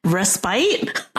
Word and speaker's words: respite: Respite? – I know, but it's respite.